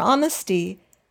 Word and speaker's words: honesty: H is pronounced for the horrid things that we dread, but H is silent for honesty, the right path to tread.